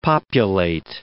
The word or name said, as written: populate